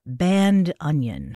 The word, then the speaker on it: bandoneon: And a note on the pronunciation, I love this, of the word bandoneon. One of my music pals, Emmanuel Trifilio, is a professional bandoneonista. And in order to make it easier for non-Spanish speakers to pronounce, he very graciously invites you to say band onion.